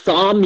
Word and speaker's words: Saam: It's Sam, yeah. Yeah, my middle name is Sam. It's spelled S-A-A-M.